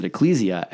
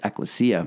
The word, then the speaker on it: ecclesia: We always pronounce it ecclesia. And then the wonderful, brilliant Mark Buchanan came and he said, actually, it's ecclesia.